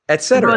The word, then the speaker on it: et cetera: You just said it right that time. Et cetera.